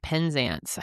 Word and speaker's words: Penzance: Now, the race, which began in Penzance, England, I think we're saying that right. – Penzance.